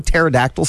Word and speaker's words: pterodactyl: Pterodactyl should start with a T. There's a silent P, and that's dumb.